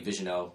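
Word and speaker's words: Vigneault: I'll also throw in that they've gotten the permission to speak to the former Vancouver Canucks head coach, I want to say Vigeneau. I haven't heard his name pronounced, so I don't know how it's properly pronounced. – I think that's it, actually.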